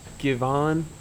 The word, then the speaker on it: Givan: How do you say it? – Marcus Givan, probably. – Probably. What does your dad say? – He says Givan, but I think a lot of that's just stemmed from his Hollis Queens upbringing.